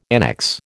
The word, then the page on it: annex: American English